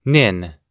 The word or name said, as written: nin